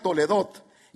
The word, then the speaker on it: Toledot: Esa se pronuncia Toledot y es la número H8435 del diccionario Strong.